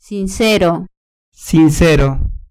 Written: sincero

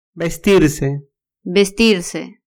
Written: vestirse

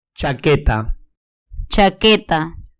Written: chaqueta

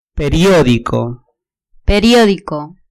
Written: periodico

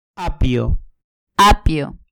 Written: apio

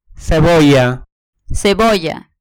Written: cebolla

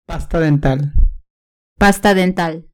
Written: pasta dental

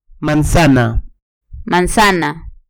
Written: manzana

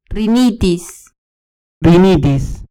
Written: rinitis

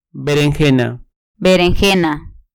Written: berenjena

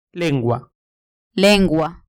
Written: lengua

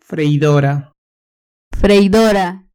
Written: freidora

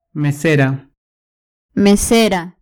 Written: mesera

